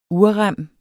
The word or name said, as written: urrem